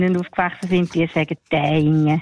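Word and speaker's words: Thayngen: Habe ich das richtig ausgesprochen? – Taingen.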